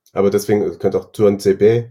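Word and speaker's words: Tuanzebe: Stimmt, Tuan Sebe wird da so ausgesprochen. – Genau. Ich habe mir ein Twitter-Video von Aston Villa mal angeguckt. Das T vorne spricht der selber wie so ein Tuan Sebe.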